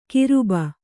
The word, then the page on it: ಗರ್ಭ: kannada